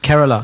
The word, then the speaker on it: Kerala: Kerala.